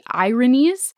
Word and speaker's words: Erinyes: So the Furies are also known as the Irinies, and not like irony, but like it's spelled E-R-I-N-Y-E-S.